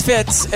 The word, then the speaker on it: Fitz: My name is Fitz, and I spell that F-I-T with a Z.